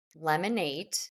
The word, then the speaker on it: Lemon8: And for those that don't know, Lemon8 is, which it's spelled L-E-M-O-N, and then it's the number 8.